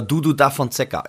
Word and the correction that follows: Duduka da Fonseca: Ich weiß nicht, ob es richtig ausgesprochen ist. Kennst du den? Äh, zu zweitmal Schande über mein Haupt für heute. So peinlich, ey. Musst du mich hier so bloßstellen? Alter, kennst du den echt nicht? Nee, oder du hast den Namen komplett falsch ausgesprochen. das, will, das, will, ich auch nicht ausschließen. Nein, also Duduka da Fonseca.